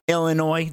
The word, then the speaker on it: Illinois: Illinois, the S is silent, at Nebraska at 9 o'clock.